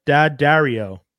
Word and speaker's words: Daddario: I always feel like I say her name wrong. So I'm gonna, let me just actually look it up. Alexandra Daddario, if anyone knows it.